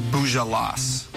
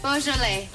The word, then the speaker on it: Beaujolais: Boujolas. Boujolas. – Beaujolais.